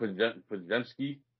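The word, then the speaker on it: Podziemski: I have such a hard time pronouncing his last name. – He's called Podemski. – Podemski from Santa Clara.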